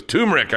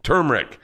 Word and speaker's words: turmeric: And Georgia goes, turmeric. I go, no, it's turmeric. And they're like, no, you don't pronounce the R.